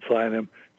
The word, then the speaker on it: sayanim: I just sent you a couple of things on Psionym just in the last 15 minutes. It's spelled S-A-Y-A-M. Let me see here. I want to get the spelling right here. S-A-Y-A-N-I-M, Psionym.